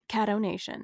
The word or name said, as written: Caddo Nation